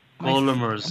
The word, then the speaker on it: gullimers: Gullimers, am I saying that right? – Yes.